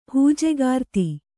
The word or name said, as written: ಹೂಜೆಗಾರ್ತಿ